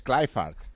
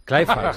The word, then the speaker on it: Kluivert: Sí, no, y Kluivert es Clifford. – Clifford, sí, sí, sí.